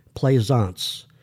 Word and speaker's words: Plaisance: You can follow me by typing into the search box my name, Michael Plaisance. That's spelled P as in Paul, L-A-I, S as in Sam, A-N-C-E.